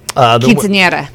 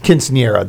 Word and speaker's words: Quinceañera: Quinceañera. – Quinceañera, thank you.